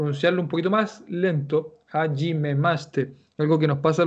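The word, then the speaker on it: hajime maste (はじめまして): En japonés, eh, hajime maste, es hajime maste. Aunque lo quieras decir rápido, sigue siendo hajime maste.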